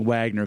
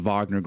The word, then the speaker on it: Wagner: So you have, out of nowhere, it seemed, the Wagner Group, I keep thinking Wagner because that's the way it's spelled, but they're saying like the Wagner Group, went in and started to attack Moscow almost.